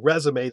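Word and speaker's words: résumé: Same with cash, C-A-C-H-E. – True, exactly. Well, I mean, with cash, the E is silent, and then with resume, the E is pronounced, too, exactly.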